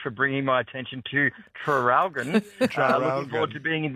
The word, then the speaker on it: Traralgon: Seeing as you asked about the spelling of Traralgon, could you please pronounce the first R? – Oh, Traralgon.